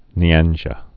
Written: Nyanja

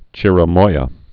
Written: chirimoya

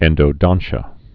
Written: endodontia

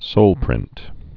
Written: soleprint's

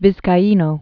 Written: Sebastian Vizcaino

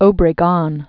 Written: Alvaro Obregon

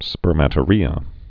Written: spermatorrhea

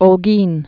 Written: Holguin

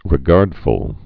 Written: regardful

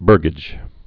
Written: burgage

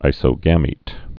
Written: isogametic

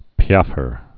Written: piaffer

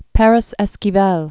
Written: Adolfo Perez Esquivel

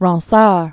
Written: Pierre de Ronsard

